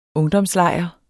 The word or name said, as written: ungdomslejr